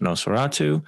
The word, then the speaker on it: Nos4A2: Also, very recently, we've had the television series Nos4A2, pronounced Nosferatu, which screened for 2 seasons on AMC, starring Ashley Cummings and Zachary Quinto.